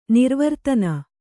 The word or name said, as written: ನಿರ್ವರ್ತನೆ